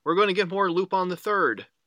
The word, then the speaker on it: Lupin: No, it's Lupin. That's still the same thing.